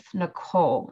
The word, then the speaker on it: Nicole: And you can follow me on Instagram at Healthy Travel with Nicole.